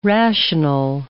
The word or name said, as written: rational